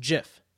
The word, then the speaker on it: GIF: He said it's a soft G, pronounced GIF.